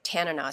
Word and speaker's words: Tanana: See, you, you mispronounced Tanana. In Alaska, we say it Tanana, so I'm going to get really confused there.